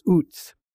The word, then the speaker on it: Uz: We don't really know where Uz, it's pronounced Uz, where it is, but it seems to be in northern Arabia.